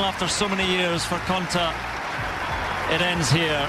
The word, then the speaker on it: Konta: beating Johanna Konta and Magdalena Rybarikova.